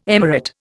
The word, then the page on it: emirate: Indian